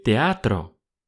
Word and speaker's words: teatro: El teatro. El teatro. Обратите внимание на произношение буквы Т. В русском театр, в испанском глухая Т.